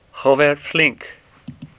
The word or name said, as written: Flinck